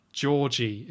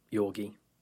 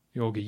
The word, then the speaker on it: Georgi: Georgie in all my notes, or Yorgi, I think it's pronounced later. – After a certain point, I think it's generally just people call him Yorgi. – Yorgi, yeah,